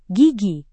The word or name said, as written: gigi